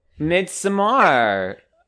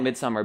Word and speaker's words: Midsommar: I don't like when people call it Midsummer, because if it was Midsummer, then it would be spelled Midsummer, but it's spelled Mid S-O-M-M-A-R.